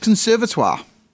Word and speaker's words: Conservatoire: Conservatoire, is that a word? I said it wrong, mate. What is it? What should I have said? – What did you say? Conservatoire? – I said conservatoire. – That's right. – Oh, okay, I said it right then.